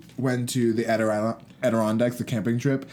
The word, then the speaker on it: Adirondacks: And we, well, because it's exclusive, but we went up to the Adirondacks and we had to go through, you're so stupid.